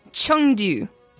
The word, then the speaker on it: Chengdu: And that are the Toronto Defiant, the Hangzhou Spark, the Paris Eternal, the Guangzhou Charge, and the Chengdu Hunters. And I'm sorry. – Nice pronunciation. – I'm trying to learn how to say it properly, and it's taken a while. So let's recap. Hangzhou, Guangzhou, Chengdu.